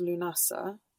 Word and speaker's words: Lughnasadh: So yeah, it's the first harvest, and it's actually, if you look at the spelling of it, it's spelled Lughnasadh. It's connected with the Celtic god Lug, which is a beginning part of the name of the day. – Yeah. Right. Yeah. But, yeah. – Although it's pronounced Lunasa, mm-hmm.